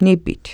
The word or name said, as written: nipit